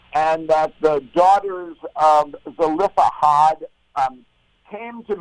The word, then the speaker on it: Zelophehad: And in particular, we're going to chat about a very interesting episode known as the Daughters of Zelophehad. Am I pronouncing that correctly? – I don't know if there's one right, but I do the same way.